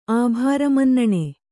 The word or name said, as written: ಆಭಾರಮನ್ನಣೆ